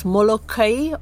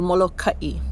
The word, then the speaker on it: Molokai: The name of our island can be pronounced two ways, Molokai or Molokai.